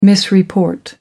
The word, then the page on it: misreport: American English